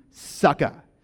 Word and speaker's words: Sukkot: And The Hebrew word for booth is spelled S-U-K-K-O-T, but it's pronounced like, I'm going to get you sucka. So can everyone say sucka?